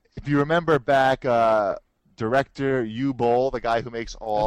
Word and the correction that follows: Uwe Boll: it's pronounced Uwe. – It is? – Yeah, Uwe Boll.